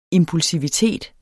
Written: impulsivitet

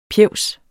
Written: PJEVSENE

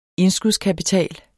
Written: indskudskapital

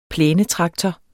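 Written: PLÆNETRAKTOR

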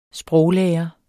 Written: sproglærer

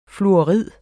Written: fluorid

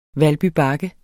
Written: bakke

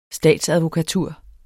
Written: statsadvokatur

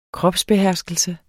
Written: kropsbeherskelse

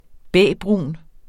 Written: bæbrun

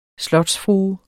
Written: slotsfrue

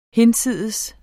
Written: hinsides

